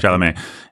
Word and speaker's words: Chalamet: Chalamet, or however you say that guy's name. Chalamet? How do you say that guy's name? – Chalamet.